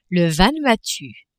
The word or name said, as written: Vanuatu